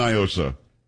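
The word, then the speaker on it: NIOSA: Do not, because it's definitely Nyosa. It is not a Spanish word. What does Nyosa mean in Spanish? Nothing. – So, I don't know. – Well, that's the whole argument. They try to pronounce it like it's Spanish. It's not. It's an acronym.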